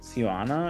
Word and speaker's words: Cuyana: And then further on in this list, there's also um, Siwana, I think is how you say it, C U Y A N A, which is a fashion brand that sells clothing and um, single origin cashmere cardigan.